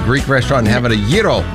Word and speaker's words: gyro: Also, gyro day. Did I say it right? – Yes. – Gyro, not Bravo.